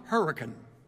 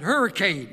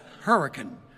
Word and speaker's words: Hurricane: Mark was born and raised in Putnam County, West Virginia, in the town of Hurricane. He taught me to say it right, because I said hurricane. He said, no, that's the way Yankees pronounce it. It's called Hurricane. Hurricane. Say it right. Okay, Hurricane.